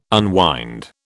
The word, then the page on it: unwind: British English